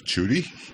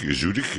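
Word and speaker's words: Zürich: а потом как «цюрих», а потом уже как «цюрищ». Вот и все. – Как это звучит сегодня? – Цюрих, Зюрих на немецком, Зюрих на французском, в общем-то, одинаково почти что.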